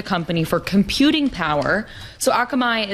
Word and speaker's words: Akamai: Akamai, am I saying it right? – Yeah. Akamai. Mm-hmm.